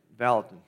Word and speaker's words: Vallotton: Chris Vallotton, or Vallotton, I still don't know how to say his name. Yeah, Vallotton. All right, I had it right the first time.